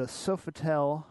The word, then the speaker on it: Sofitel: We want to remind you XBiz LA is happening February 9th through the 11th at the Sofitel Hotel. Did I get that right? – I think so.